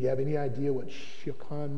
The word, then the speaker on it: Siochan: Siocan. It's spelled in English letters S-I-O-C-H-A-N, but that S-I-O sounds like she.